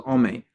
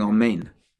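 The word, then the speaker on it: amen (אמן): And not cut off if the nun at the end is not clearly pronounced. If he says ome instead of saying amen.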